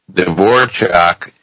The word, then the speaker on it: Dvorak: Now, Dvorchak is spelled D-V-O-R-A-K. I'm pronouncing it like the composer's name.